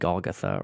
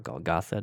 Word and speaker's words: Golgotha: He says, finally, they came to the place called the Skull, or sometimes called Golgotha or Golgotha, depending on your pronunciation.